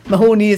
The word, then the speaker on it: Mahoney: Mahoney is how they pronounce it.